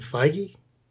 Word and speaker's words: Feige: I hope I don't mispronounce his name, Kevin Feige.